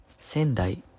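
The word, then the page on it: 仙台市: Sendai; [seꜜn.dai, sen.daꜜi.ɕi]; Japanese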